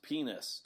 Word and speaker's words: Peaness: And the group, it's the band name is spelled P-E-A-N-E-S-S, pronounced penis.